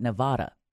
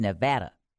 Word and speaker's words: Nevada: I know folks in Nevada hate it when you call it Nevada. It's Nevada.